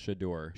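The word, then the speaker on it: Shedeur: One thing I wanted your opinion on as well, because I found it weird, was Sadori. – I think it's pronounced Shador.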